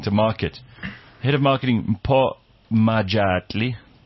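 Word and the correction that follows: Mpho Majatladi: No, hang on, let me say that properly. Majatladi. Yeah, Mpo Majatladi said, passengers had access to staff at all the airports and they could also call the reservations department for assistance.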